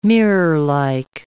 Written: mirrorlike

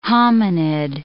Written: hominid

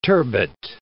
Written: turbot

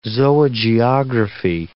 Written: zoogeography

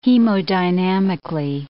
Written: hemodynamically

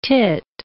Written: teat

